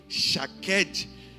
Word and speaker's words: shaqed: É a palavra shaked, é, no português seria escrito S-H-A-Q-E-D, shaked.